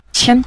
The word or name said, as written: qian5